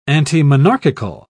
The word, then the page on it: antimonarchical: British English